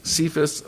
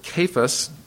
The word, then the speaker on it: Cephas: It's Cephas, or I actually heard people pronounce it Cephas, at any rate.